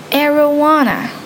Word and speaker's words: arowana: I think it is called arowana, like marijuana. Yeah, kind of the pronunciation, it's really tricky.